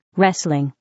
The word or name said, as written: wrestling